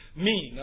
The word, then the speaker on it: mien: And the word main, M-I-E-N, it's pronounced mean. I'm sorry, not main, but mean.